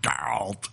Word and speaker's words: Geralt: That's how you say it, by the way. Geralt.